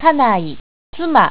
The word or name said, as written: kanai